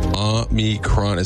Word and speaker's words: omicron: Officially, almost anything works. Omicron. Omicron is how they say it, or Omicron. Both are fine. The Oxford Dictionary includes Omicron. So the classical Greek, it was pronounced the second syllable, like me.